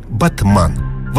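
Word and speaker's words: Batman: Правда, сами турки немного коверкают это заморское слово и произносят его на свой лад. Батман. Вот так, буквы английские, ударение турецкое.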